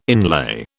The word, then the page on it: inlay: British English